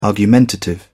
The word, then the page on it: argumentative: British English